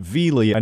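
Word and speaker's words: Viele: Point to make about Vealy, I now know the name is pronounced Vealy because it's on his personal Twitter page. He clarifies the pronunciation as V-L-E-E, Vealy.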